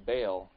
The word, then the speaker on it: Baal: But then he marries this princess from another nation named Jezebel, who influenced him to start worshiping her god named Baal, or some people pronounce it Baal. It's B-A-A-L is how it's spelled.